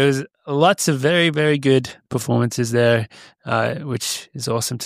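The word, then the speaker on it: Bol: Gout, not how you say his name, but Good, isn't it? – Good. – Because his dad's like told the media that it's not incorrectly pronounced and they're still pronouncing it Gout.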